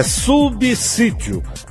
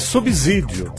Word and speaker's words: subsídio: A gente pronuncia com som de Z. É subsídio. Mas tem muita gente que diz subsídio. Não é. É com S.